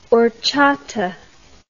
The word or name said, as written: horchata